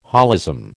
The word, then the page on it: holism: American English